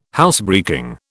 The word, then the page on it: Housebreaking: British English